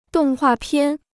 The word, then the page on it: 动画片: dòng huà piàn